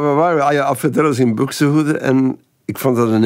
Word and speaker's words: Buxtehude: En ik heb die LP van Alfred Deller zingt op de A-kant muziek van Buxtehude. Ik weet niet hoe je het moet uitspreken. – Ja, dat is helemaal juist.